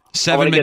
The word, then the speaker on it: Seven: Seven McGee. – Get the name right. – Yeah, yeah, Seven, yeah. Like the number Seven, S-E-V-E-N is his first name.